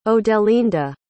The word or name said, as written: odelinda